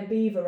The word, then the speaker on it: Belvoir: Yeah, I'm living near Beaver right now. – Yeah. – And it's spelt like Belvoir.